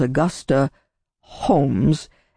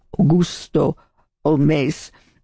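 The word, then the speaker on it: Augusta Holmès: So her name was Augusta Holmes in the Anglo pronunciation, but she adopted a kind of French pronunciation. So Augusto Holmes also was the way her name came to be pronounced.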